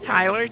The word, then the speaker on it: Tyler: Okay, that's Tyler, T-Y-L-E-R.